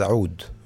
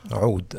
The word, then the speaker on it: oud: Yeah, so actually the name pronounced correctly in Arabic is oud. With the ayn, and in your throat. – Yeah, ayn, oud.